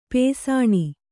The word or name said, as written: ಪಾಸಾಣ